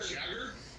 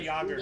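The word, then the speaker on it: Jágr: His last name is J-A-G-R. Jager. J-A-G-R. I was like, is it Jagger? – Jagger. – It's pronounced Yamir Yager. – Yager, yeah.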